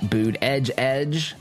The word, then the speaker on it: Buttigieg: Talking about Pete Buttigieg. Edge. Make sure you say that properly, America.